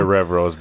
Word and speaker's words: Revros: We got God, I'm going to butcher the name here, but Daiwa Revros, I'm going to call it. – Probably Revros because Abu Garcia has the Revos. – The Revos, so. Yeah, R-E-V-R-O-S, Revros, Revros, I don't know, one of them too.